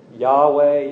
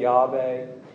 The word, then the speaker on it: Yahweh: Some pronounce it Yahweh, Yahweh. Uh, others have put uh, vowels in there from Adonai, and they pronounce it Jehovah.